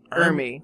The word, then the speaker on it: Ermey: Jr. His dad was Arlie Ermey, or is it Emery? I don't know how to pronounce it. – Arlie Ermey.